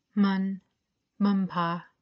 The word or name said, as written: Hmun-hmunpa